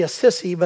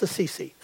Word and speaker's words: Assisi: Now, it's supposed to be a sissy, but if I say that, I'll get letters and, and they'll say you're not saying it right. But okay, Francis Assisi.